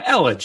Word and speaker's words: Elledge: Yeah, Elledge.